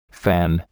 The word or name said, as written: fan